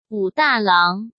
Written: Wu Dalang

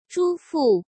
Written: Zhu Fu

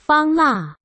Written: Fang La

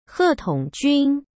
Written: He Tongjun

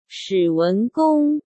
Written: Shi Wengong

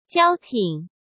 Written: Jiao Ting

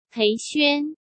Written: Pei Xuan

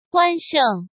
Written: Guan Sheng

